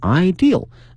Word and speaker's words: ideal: 这里面又碰到了这个 destination, D-E-S-T-I-N-A-T-I-O-N, 表示目的地啊 ,destination, 前面的加上 ideal, 理想的啊，它不是那个 idea, 是 ideal, 后面加一个 L, 啊，它的发音也不一样啊，想法主意呢是 idea, 啊，如果说是一个理想呢是 ideal, 后面加一个 L, 啊，所以说同学们要区分出啊这两个词的区别。